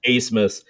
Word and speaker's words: Abmas: For Texas, ABMAS, and his name is pronounced Asemus, their leading scorer, who had a 20-point game against us, Max Asemus, was absolutely held in check.